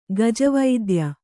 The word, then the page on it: ಗಜವೈದ್ಯ: gaja vaidya